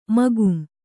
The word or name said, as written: ಮಕಾಂ